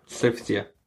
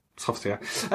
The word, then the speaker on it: Sophitia: Sophitia. Or Sophitia. It's like Sophie, but it's got sha on the end. T-I-A. Tia. – Yeah. – Sophitia. Sophitia. Anyway, she's in it.